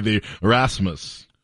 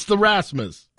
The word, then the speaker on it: Rasmus: It's In the Shadows by the Rasmus.